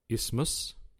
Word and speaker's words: isthmus: One of the most difficult words in the world to pronounce is the word isthmus, but there's a silent T. Why are you laughing, Paul? I-S-T-H-M-U-S, right? – I-S something or other.